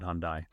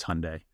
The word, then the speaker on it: Hyundai: I'm learning that it's not Hyundai, that it's Hyundai. – Rhymes with Sunday, yeah.